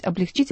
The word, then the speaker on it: облегчить: Так что, извините, пожалуйста, конечно, «облегчить». Нужно говорить «облегчить», и мы все это запомним.